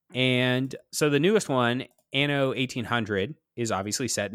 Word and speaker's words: Anno: Is it Anno or Anno? It's Anno.